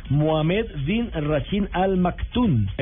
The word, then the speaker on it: Mohamed bin Rashid Al Maktoum: Mohamed bin Rashid Al Maktoum.